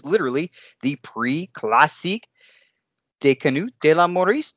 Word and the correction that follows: Mauricie: In French, it's classic International de Canots de la Mauricie.